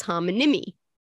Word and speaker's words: homonymy: Mononym was a word on Monday 2/20, as was homonym, as was homonymy. Or how do you say homonymy? – Homonymy. Yeah.